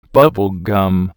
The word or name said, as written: bubble gum